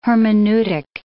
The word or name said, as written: hermeneutic